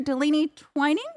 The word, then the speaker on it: Delaney Twining: But right now, I want to thank you to our Patreon producer, Delaney Twining.